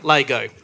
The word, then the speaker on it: Lego: Lego. Like, correctly. That's how we pronounce it. – Yeah. Right, like it's spelled. L-A-G-O. – Yeah. L-A-Y-G-O.